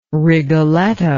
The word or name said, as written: Rigoletto